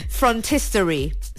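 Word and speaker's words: phrontistery: Frontistory. – Sorry? – Frontistory. – Frontistory. – So it's spelled P-H-R-O-N. Front. – Front. – Tistory. T-I-S. – Tistory. – Yeah. Tory. Yeah. Frontistory. T-E-R-Y. Okay.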